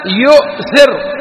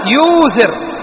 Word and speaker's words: يؤثر: بالواو، نأتي بالواو، فنقول يؤثر أبقينا الهمزة، أو تقول يوثر، والله فلان هذا يوثر على نفسه، صحيح ولا لا؟